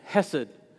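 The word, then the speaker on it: hesed (חסד): but the Hebrew word is hesed. And I'm not pronouncing it the way it should be pronounced because I don't want to gross you out. But it's hesed. And it sounds like you're clearing your throat when you actually say the H-E on the front end.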